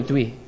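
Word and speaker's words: Dieu: Other places in Africa that are French-based refer to a pastor as Docteur de Douy. I hope I said that right, Musée. Did I say it right? No, Douy. This is Douy.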